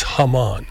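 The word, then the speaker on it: jamón: But the it's spelled J-A-M-O-N. But it's Haman.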